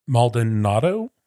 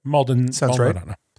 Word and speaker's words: Maldonado: I'm going to probably butcher this. Maldonado. – Yeah, that sounds right. – Maldonado.